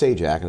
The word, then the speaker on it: Sajak: I think if you were in Warsaw, my name was spelled Sajdak, and I think it would have been pronounced Sajdak if I'm not mistaken. So I grew up with that extra D, and it was pronounced Sajak, and it drove the teachers crazy.